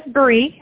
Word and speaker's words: Bree: B-R-I-A-N-N-A. Have I got that spelled right? – My name is Bree. I spell it B-R-E-E.